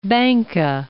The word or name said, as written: Banka